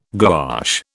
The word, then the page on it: gouache: British English